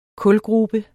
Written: kulgrube

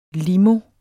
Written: limo

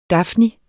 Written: dafnie

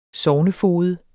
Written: SOGNEFOGED

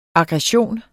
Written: aggression

